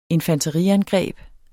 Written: infanteriangreb

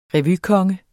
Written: revykonge

